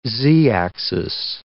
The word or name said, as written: z-axis